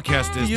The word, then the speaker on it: Kestis: It's Kestis.